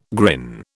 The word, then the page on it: grin: British English